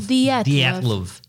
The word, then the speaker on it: Dyatlov: Say like you were Russian, like Blyet. Dyatlov. – Dyatlov.